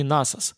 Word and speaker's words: Насос: Или же просто не обратили внимания, увлекшись вечерним досугом?» Вот и суши-бар, где балом правит вреднющий официант по имени Насос. Паразит и халтурщик, дать бы ему в репу от души. Ударение в имени на первый слог, однако я называю его более привычно, добавляя закономерный эпитет «грёбаный».